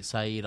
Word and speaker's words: Said: Is it S-A-I-D, like Saeed? – Yeah. – Saeed? – S-A-I-D, Saeed Ahmed.